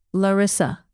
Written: larissa

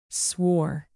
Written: swore

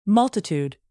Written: multitude

